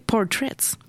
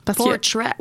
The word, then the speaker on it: Portrait: EP, qu'on peut prononcer Portraits. – Portraits. – Ouais, il y a trois tunes en français, trois tunes en anglais. Donc, quand j'ai demandé à Olivia Coury en entrevue si c'était un EP francophone ou anglophone, ben, comme elle est bilingue, elle aussi, elle m'a dit qu'on pouvait dire ça comme on voulait.